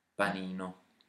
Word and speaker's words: panino: Panino.